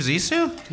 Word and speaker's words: Zissou: The Life Aquatic with Steve Zissou? – Yay!